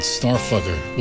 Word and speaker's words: STRFKR: But, band's called Starfucker, with all the vowels removed. Which makes you wonder, how do they make music with all their vowels removed? But anyway, it's spelt S-T-R-F-K-R.